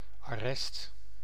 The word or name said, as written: arrest